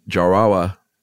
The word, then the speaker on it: Jarawa: Notably in India's Andaman Island, the Jarawa. Mm-hmm. Sounds like I'm saying that wrong, but that's totally right. – I think you nailed it.